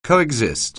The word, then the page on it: coexist: anglais